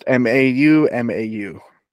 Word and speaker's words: Mau Mau: Um, and it's also similar to a traditional European game called Mau Mau. – Okay. that's how it's pronounced. M A U M A U. Mau Mau.